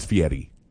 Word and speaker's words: Fieri: That's how you know who Guy Fieri is the hair. – Is that how you say it? – And the sunglasses. It's Fieri.